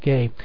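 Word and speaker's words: ge: And this word field is the word gay, I believe it's pronounced. It's spelled G-E, gay.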